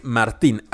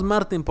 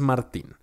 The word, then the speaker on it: Martín: Henry Martín, así con acento en la I, porque hay algunos que dicen, ah, pues conviene más si le decimos Martín porque es Henry. No, no, no, es Martín.